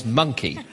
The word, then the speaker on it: Moncke: Monkey. Although it's spelt M-O-N-C-K-E. And he was a 17th century London blacksmith, presumably of German extraction, whose foreign-sounding name is likely to have been pronounced monkey by locals.